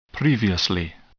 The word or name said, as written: previously